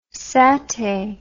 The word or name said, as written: satay